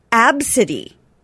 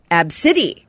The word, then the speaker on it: Abcde: Now, the girl's name is pronounced Absidy. Absidy.